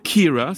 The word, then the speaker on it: Ciara: Je vous propose de poursuivre notre voyage en Irlande en compagnie d'une chanteuse qui s'appelle Kira, ça s'écrit C-I-A-R-A, Kira Mary Alice Thompson, ce qui donne les initiales C-M-A-T, hein, C-M-A-T,